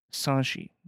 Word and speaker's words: Sanxi: It's spelled S-A-N-X-I. But from my best estimate, that's Sanchi Daguerre.